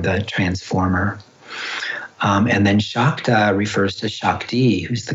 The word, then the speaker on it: Shakta: So when the S has a little accent over it in Sanskrit, it's pronounced as an SH. So it's actually Nandu Shaiva Shakta Tantra.